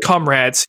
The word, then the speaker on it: Comrades: Comrades. You guys, there is a word in the English language called comrade. I don't understand how you keep pronouncing this wrong.